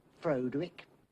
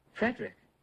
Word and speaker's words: Frederick: Do you also say Frodwick? No, Frederick. Well, why isn't it Frodwick Frankenstein? It isn't. It's Frederick Frankenstein.